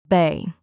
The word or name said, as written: bay